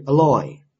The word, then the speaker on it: alloy: 当动词的时候，它的发音是 alloy。